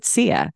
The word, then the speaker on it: CEA: And so we pronounce it CEA. – Okay. – Like the singer.